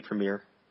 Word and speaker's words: premier: They introduced the concept of tiers of play, splitting competitive play into casual, competitive, and premier. Premier? How do Anglophones pronounce that word? – I think I would say premier. – Premier, okay. So I'm not sure why they're making this distinction.